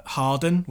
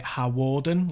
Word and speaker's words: Hawarden: they mentioned Harden, which, you know, sounds quite funny if you say it wrong anyway, which is like, you know, in North Wales and near where I live. And they pronounced it Hawarden, like spelled it out.